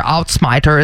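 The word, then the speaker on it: Uitsmijter: Outsmiter is how you say it, though.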